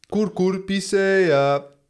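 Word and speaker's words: Cucurbitaceae: Hoe spreek je dat uit? – Cucurbitacea. – Precies.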